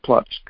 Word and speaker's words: Płock: But the original name of the city is P-L-O-C-K, and it's pronounced Płock.